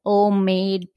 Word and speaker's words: Omade: That way they'll know where to find Omade PB. – Yeah, it's all of my my website, all of my social handles. They're all Omade PB. And I tell people OMADE rhymes with homemade.